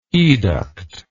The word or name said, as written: educt